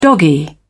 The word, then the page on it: doggy: British English